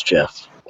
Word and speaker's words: GIF: So you said it's pronounced GIF. Yeah, the creator says it's GIF.